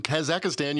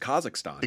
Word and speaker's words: Kazakhstan: See, McKinley just messed up because it's Kazakhstan.